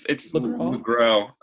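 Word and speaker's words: Le Gros: And then in the other corner, in the other corner, weighing in as the amazing, legendary actor, Noah LaGrosse. Am I pronouncing that right? – It's close. It's LaGrosse. – LaGrosse, yeah.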